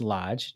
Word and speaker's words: Loge: The next one, the Lodge Hotel, which is spelled L-O-G-E, but pronounced Lodge, just so you know.